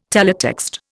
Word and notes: teletext: Indian

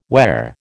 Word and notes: whare: American English

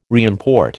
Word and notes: reimport: American English

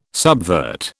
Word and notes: subvert: British English

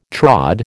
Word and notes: trod: American English

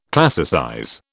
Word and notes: classicize: British English

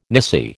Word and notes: nisi: American English

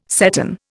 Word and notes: seton: Indian

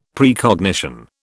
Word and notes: Precognition: British English

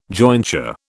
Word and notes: jointure: British English

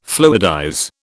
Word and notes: fluidize: British English

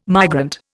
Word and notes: migrant: Indian

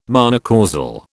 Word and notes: monocausal: British English

